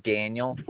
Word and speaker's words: Daniel: Yes, Daniel Brown. – And it's spelled D-A-N-I-E-L.